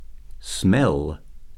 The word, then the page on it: smell: verb